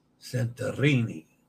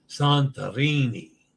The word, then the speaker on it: Santorini: Yes, yeah, Santorini has an open A as in father. And roll the R. Santorini. Father. Santorini. Santorini.